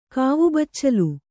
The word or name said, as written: ಕಾವುಬಚ್ಚಲು